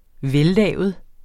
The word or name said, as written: vellavet